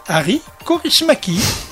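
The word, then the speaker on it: Aki Kaurismäki: Oui, Ari Kaurismäki.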